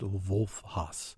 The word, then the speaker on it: Haas: It's called The Weather 15 Years Ago by an Austrian writer named Wolf Haas, or I guess it's pronounced Wolf Haas, H-A-A-S.